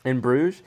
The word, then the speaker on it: In Bruges: In Bruges might be how it's pronounced. I'm horrible at pronouncing. – Embroidered. – In Bruges? – Yes, it's embroidered. – Yeah.